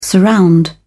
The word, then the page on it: surround: British English